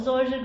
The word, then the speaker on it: zoysia: My dad, unfortunately, had a love of zoysia grass, spelled Z-O-Y-S-I-A, but pronounced zoysia.